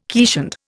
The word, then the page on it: keeshond: Indian